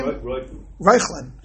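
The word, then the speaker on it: Reuchlin: How do you pronounce his name? – Reichlin.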